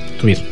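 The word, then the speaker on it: Týr: Això era un tros de la cançó Ormurin Langi del grup Tyr, és un grup feroès, perdó, es pronuncia en Tuir. – Tuir.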